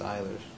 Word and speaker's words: Ehlers: I couldn't pronounce it. – Spell E-H-L-E-R? – E-H-L-E-R-S. – I think it's Ehlers. – Ehlers, okay. – I could be wrong, though.